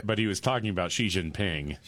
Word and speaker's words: Xi Jinping: I was in the foothills of the Himalayas with Xi Jinping.